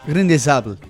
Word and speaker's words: Grain de Sable: ¿Cómo se dice? – Grain de Sable.